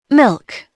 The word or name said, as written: milk